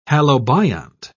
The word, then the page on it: halobiont: British English